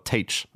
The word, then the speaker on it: Tage: Übrigens, Tage Thompson wird geschrieben Tage Thompson, heißt aber Tage, auch das wieder. Ne? So Wissen für alle, die ein bisschen glänzen wollen damit, wenn man sich fragt, wie der denn ausgesprochen wird. Tage, also quasi wie ein Bayer, ne? Also Tage.